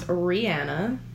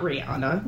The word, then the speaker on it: Ryanna: Okay, so my name is Rihanna. As in, not Rihanna. – Rhymes with banana.